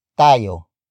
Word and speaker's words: tayo: For example, ano tayo? Ano tayo? Ano tayo? It literally means, what are we? But it can also mean, what is our dating status? Word for word it's, ano? Tayo.